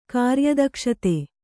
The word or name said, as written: ಕಾರ್ಯದಕ್ಷತೆ